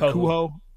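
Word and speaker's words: Kohou: I mean, like Cater is how we say his last name. – Kohu. – Kohu.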